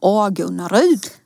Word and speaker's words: Agunnaryd: Ja, jag skulle säga att det uttalas Ingvar Kamprad Älmtaryd Agunnaryd.